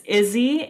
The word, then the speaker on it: Izzie: It's Izzie and ADHD, and Izzie is spelled I Z Z I E.